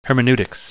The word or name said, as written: hermeneutics